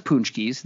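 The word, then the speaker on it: pączki: Because here's the thing with Punchkis is that everyone says Poonchkeys. They are Poonchkeys.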